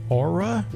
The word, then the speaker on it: Oura: It's a ring that is, I'm sure it's pronounced Aura. Would you not think? It's spelled O-U-R-A.